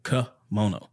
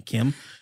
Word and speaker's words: kimono: Yeah, and if you look up like kimono, it's like kuh. Mono. – Yeah. – Like, yeah. – That's how you pronounce it, Cub. But they were probably saying Kim.